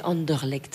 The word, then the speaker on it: Anderlecht: Mais j'avais essayé une sauce, une fois ils avaient sorti une sauce violette pour Anderlecht, en hommage à Anderlecht, et franchement, c'était pas terrible. – Ouais, excusez-moi, mais on dit Anderlecht.